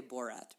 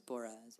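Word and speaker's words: Boras: I always want to say Borat, but it's Boraz.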